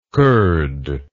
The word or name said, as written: koord